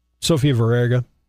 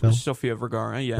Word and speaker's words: Sofia Vergara: Sofia Vergara, TV actress. – Sofia Vergara. – Yes, sorry. – Modern Family. – Butcher her name.